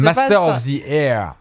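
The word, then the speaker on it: Masters of the Air: Tu sais que l'accent que tu prononces, on dirait Master of the Oreille. Hein. – Oui, c'est... – Comment tu prononces? – Oui. Master of the Air.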